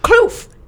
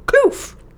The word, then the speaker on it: kloof: Oh, I thought you were just mispronouncing cliff like a goofball. – Nope. Kloof. K-L-O-F-F. Kloof.